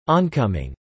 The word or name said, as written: oncoming